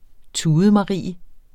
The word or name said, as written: tudemarie